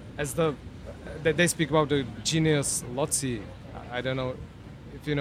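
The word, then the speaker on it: genius loci: We pronounce it genius Lotzi. It's in Latin.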